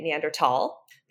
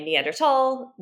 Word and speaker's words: Neanderthal: When you look at the word, if you're an English speaker, you might think to say thal, because that's how we pronounce T-H. If you were a German speaker, you would say tall. And therefore, either when you're saying the colloquial name Neanderthal, either is correct.